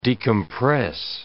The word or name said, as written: decompress